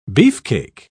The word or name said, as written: beefcake